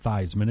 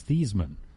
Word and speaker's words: Theismann: It's not Theismann, it's Theismann." – Oh, seriously? – And the guy said to his dad, "Well, yeah, but Theismann rhymes with Heisman."